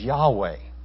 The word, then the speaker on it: Yahweh: And so here we find this word Lord that is really the personal name of God, which most likely is to be pronounced as Yahweh.